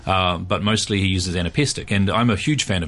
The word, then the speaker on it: anapestic: I was reading about it this morning, anapestic. – That's the one. Anapestic. Anapests are a kind of, a rhyme form where there is three syllables and the third syllable is the heavy syllable, the accented syllable.